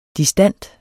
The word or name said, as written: distant